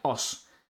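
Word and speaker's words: Us: Estamos hablando de Oz. Se escribe U de uva, S de sandía. Oz.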